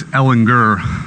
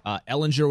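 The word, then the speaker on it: Ehlinger: I feel like the two older guys, Ellinger, with the hard G. – Good morning, Mad Radio. That was Tom Herman as part of Big 12 Media Days yesterday. So remember, Ellinger with the hard G.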